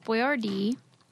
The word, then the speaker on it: Boyardee: So the way it's spelled was B-O-I-A-R-D-I. I would have pronounced it Boyardee. – Boyardee. Yeah. – But then when they changed it to be spelled Boyardee phonetically, we must have changed like the where you stress the accent.